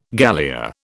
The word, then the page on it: galea: British English